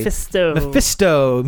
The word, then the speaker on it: Mephisto: Because Mephisto, or Mephisto, I can never say that correctly. – Mephisto. Kit Fisto.